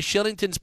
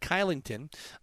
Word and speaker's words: Kylington: This says, Pat, I always wondered why Shillington's pronounced Shillington and not Kylington.